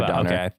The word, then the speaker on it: Donner: Yeah, it is Richard Donner.